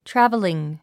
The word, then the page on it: traveling: trǽv ə liŋ; トラベリン